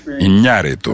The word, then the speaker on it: Iñárritu: Заметила, да, что неправильно сказал? А между прочим, на испанском просто акция «асенто графико», то есть графическое ударение есть в фамилии. Иньяриту.